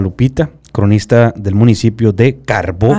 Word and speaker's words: Carbó: Acento en la O. – Carbó. – Sí, porque muchas, muchas personas, principalmente del sur de la República, lo mencionan como carbo.